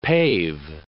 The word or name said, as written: pave